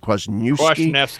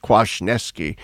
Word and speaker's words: Kwasniewski: And it's important to note here, as my friend Dr. Peter Kay, how do you pronounce his name? – Kwasniewski. – I should know this. I'm half Polish. Kwasniewski.